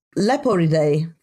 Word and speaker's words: Leporidae: They belong to the family of lagomorphs, and specifically the Leporidae family.